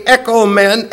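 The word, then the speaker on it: ekomen (ἔχομεν): So you literally would read this either way, ekomen, ekomen. That's the way you would pronounce it. There would be real no pronunciation difference,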